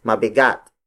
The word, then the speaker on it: mabigat: Bigat is spelled as B-I-G-A-T. When used as an adjective, bigat becomes mabigat. Mabigat. Mabigat. Mabigat is spelled as M-A-B-I-G-A-T.